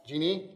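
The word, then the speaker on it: Djinni: So we have a tool called Genie, pronounced Genie. That spelling is just an alternative transliteration.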